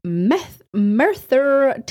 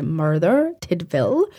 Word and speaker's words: Merthyr Tydfil: So Ian Watkins was born in Merthyr Tydfil in Wales. I think that's actually pronounced like Merthyr. But because I'm Scottish and I pronounce my R's, I'm going to pronounce it Merthyr Tydfil in Wales on July 30th in 1977.